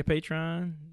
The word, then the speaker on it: Patreon: Everything Dead in Hip Hop, Dead in Podcast, Dead in Podcast Network, Patreon. What else am I forgetting? – You said it right. Yeah, Patreon.